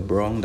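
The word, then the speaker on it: Brong: I'm your host, Uncle Brown, that's B-R-O-N-G, not B-R-O-W-N. I did it like that for the pronunciation sake, you know, for y'all to get it right.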